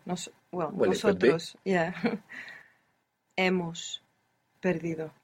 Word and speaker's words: nosotros: You pronounced an S there, which was very good. Most English speakers find themselves accidentally saying nosotros with a Z. Not nosotros, but nosotros. It's an S.